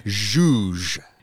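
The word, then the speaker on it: zhuzh: But we're not pronouncing this word like juj, juj, juj. Choo choo. No. How do I pronounce it? – Juge.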